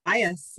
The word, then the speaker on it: Bias: Bias, yes.